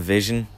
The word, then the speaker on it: VZN: I go by the name of Vision.